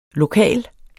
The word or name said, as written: lokal